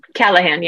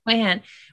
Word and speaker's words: Callahan: I felt like Callahan, is that how you pronounce his name? – Callahan, yeah. – Callahan.